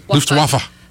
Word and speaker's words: Luftwaffe: Yep. Luftwaffe. There's no silent letters in German.